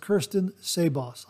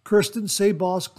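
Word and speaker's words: Kirsten Sebos: Now that's spelled K-I-R-S-T-E-N, followed by S-E, then followed by B-O-S. I believe it's pronounced Kirsten Sabos. I hope I pronounced that correctly.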